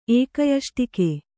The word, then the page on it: ಏಕಯಷ್ಟಿಕೆ: Kannada